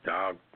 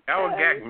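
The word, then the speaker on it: Dowagiac: This happened in how you pronounce this word? Dogmagaca? – Oh, boy. – Dowagac. – Okay. – Dowagac, Michigan. There.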